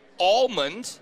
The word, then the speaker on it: almond: It's almond, pronounced as if it was A-L-L-M-O-N-D, almond. – No, not, it's almond. – No, it's not. There's no H in that. There's an L. It's a almond. Very clear L.